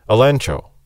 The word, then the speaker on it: elegcho: It's spelled E-L-E-G-C-H-O. But in Greek, you couldn't really pronounce G-C-H, so they pronounced it like N-C-H, elencho.